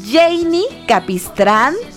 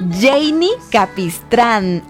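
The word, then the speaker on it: Jeinny Capistrán: Arroba Radio Disney MX, arroba Janie Capistrán. Por si no sabes cómo se escribe mi nombre, ahí te va. J E y latina, doble N Y Capistrán. Janie Capistrán.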